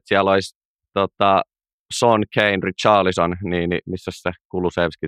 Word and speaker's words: Richarlison: Yksi iso hankinta, mikä Suomessa varmasti kiinnostaa, on tuo Richard Leeson, jos mä näen, vai Richard Leeson, vai miten se lausutaan. – Richarlison.